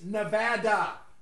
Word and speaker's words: Nevada: It's not Nevada. It's Nevada.